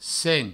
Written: sen

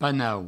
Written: panau

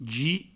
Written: gip

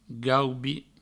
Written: gaubi

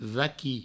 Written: vaqui

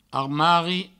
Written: armari

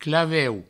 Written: claveu

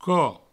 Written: cop

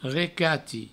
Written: recati

